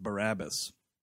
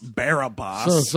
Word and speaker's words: Barabbas: I never saw it, but it's pronounced Barabbas. – Oh, okay. – Not Barabbas.